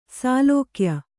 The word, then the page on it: ಶ್ಲಾಘ್ಯ: ślāghya